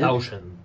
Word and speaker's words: Townshend: Pero sí después Townshend, que seguramente lo estoy pronunciando mal. – Townshend, Pete Townshend.